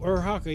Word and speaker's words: Uraraka: Like, you can see why Yureka, like, is her name Yureka? I forgot how you say it. – Yureka 7? – No. Uraka from My Hero. – Oh, Uraraka. Yeah, I love her.